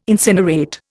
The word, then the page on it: incinerate: Indian